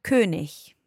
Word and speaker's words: König: Yeah, in standard German pronunciation, words that end in I-G are pronounced ich. – Ich, okay. Yeah? So give us an example of a word that ends in I-G. – König.